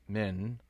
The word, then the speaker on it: MN: For those who are not from Minnesota, MN, pronounced Min, are the initials for our state.